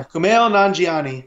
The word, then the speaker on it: Kumail Nanjiani: And it's actually starring Kumail Nanjiani. Am I saying that right? – Yeah, Kumail Nanjiani.